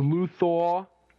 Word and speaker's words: Luthor: They should have gotten the actor who played Otis at the beginning of Luthor to be like, this episode is called Luthor. How do you say it? You could do his voice better than I can. – Mr. Luthor. – Yeah, like that.